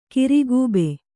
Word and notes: ಕಿರುಗಬ್ಬ: kirugabba

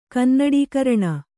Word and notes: ಕನ್ನಡೀಕರಣ: kannaḍīkaraṇa

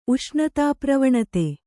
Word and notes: ಉಷ್ಣತಾಪ್ರವಣತೆ: Kannada

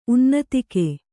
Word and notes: ಉನ್ನತಿಕ್ಕೆ: unnatikke

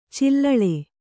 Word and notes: ಚಿಲ್ಲೆಳೆ: Kannada

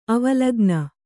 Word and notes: ಅವಲೋಕಿನಿ: avalōkini

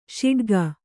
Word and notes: ಷಡಕಿ: Kannada

